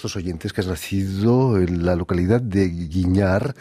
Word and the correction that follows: Güímar: De Güímar. – De Güímar, perdón. – Sí. – En las Islas Canarias. Es difícil de pronunciar. – Sí, es muy difícil porque tiene diéresis.